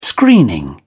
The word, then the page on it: screening: British English